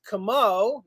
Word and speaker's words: Comeau: Como. Not Perry Como. That's Italian. We are French. – K-A-M-O-E.